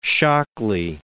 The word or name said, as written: Shockley